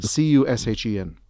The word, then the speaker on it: Cushen: Leon Cushion is a girl. – Cushen.